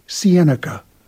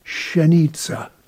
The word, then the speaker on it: Siennica: Siennica. Siennica. – Siennica. – Siennica. We learned that on the train from Warsaw to Minsk Mazowiecki. – Uczyliśmy się wymowy tej nazwy. Siennica. W pociągu do Mińska Mazowieckiego.